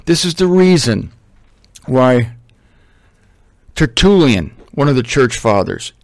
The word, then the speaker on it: Tertullian: Tertullian.